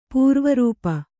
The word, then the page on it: ಪೂರ್ವರೂಪ: pūrva rūpa